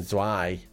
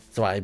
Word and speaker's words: zwei: I wasn't pronouncing the T of zwei. I was just saying zwei. Zwei be a bitter, I said, and then he came, oh no, zwei be a bitter.